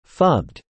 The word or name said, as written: phubbed